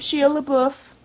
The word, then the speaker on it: Shia LaBeouf: Save Shia LaBeouf. That's how you say his name.